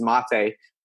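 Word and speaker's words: Máté: Eventually, I have a lot of phone calls and a lot of online meetings, so I would say about 99 times, they usually say, "How you doing, mate?" instead of mate. So basically, in order to prevent that, because I like my name to be to be pronounced the right way, I usually say, "Hey, this is Mate," before they would say, "Hey, mate."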